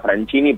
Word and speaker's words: Francini: Se escribe como mi apellido, Francini, pero se, pronuncia Francini porque es italiano.